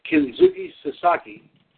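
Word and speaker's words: Kensuke Sasaki: On this date in 1997, Kenzuki Sasaki, that's how you pronounce it, defeated Shinya Hashimoto to win the IWGP Heavyweight title.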